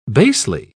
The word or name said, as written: basely